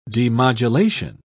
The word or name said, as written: demodulation